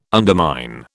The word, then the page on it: undermine: British English